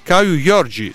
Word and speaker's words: Caio Jorge: Quindi un'informazione di servizio che potrebbe esservi utile: la corretta pronuncia di Caio Jorge è lo schio letterale Caio Jorge.